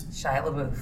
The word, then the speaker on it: LaBeouf: Shia LaBeouf.